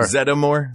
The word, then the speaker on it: Zeddemore: Zeddemore.